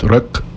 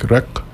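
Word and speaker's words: riq: But rak, or how do you pronounce it in Arabic? Rak? – Rak, yeah, of Arabish rak.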